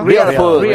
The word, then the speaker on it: Real Food: Ahora sí, estoy copiando la Real Food. Se dice así, ¿no, Alcalá? – Real Food. Sí, bienvenido al club, Oliva.